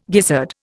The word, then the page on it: gizzard: Indian